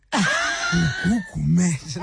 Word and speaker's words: Gugu Gumede: I'm sorry, I'm laughing because I like how you pronounce my name. Gugu Gumede, my girl.